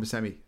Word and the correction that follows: Buscema: There's a Buscema. Sorry, yeah, I knew I was saying it wrong.